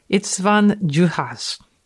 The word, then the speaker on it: István Juhász: You want to try it again? – Okay. István, that sounds good, Juhasz. – How do you spell that? – I-S-T-V-A accent aigu N. Juhasz. István Juhász.